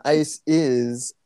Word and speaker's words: Iceis: I have one qualm, which is that her name is spelled Isis, like I-C-E-I-S, and not Isis, like the Greek or the Egyptian goddess, which is just not the choice I would have made.